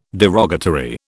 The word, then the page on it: derogatory: British English